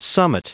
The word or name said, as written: summit